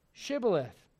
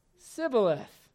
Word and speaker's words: Shibboleth: If he said nay, then said they unto him, say now, Shibboleth. And he said, Sibboleth. Are you following this? All right. And he said, Sibboleth, for he could not frame to pronounce it right.